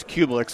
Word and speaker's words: Cubelic: I mean, it's, well, my last name's Cubelic, so that's fine. I can Gary Danielson called me Cubelic earlier on my show.